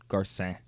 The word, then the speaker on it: Garcin: Joseph Gar- Garcine? – Garcin. – Garcin. Garcin.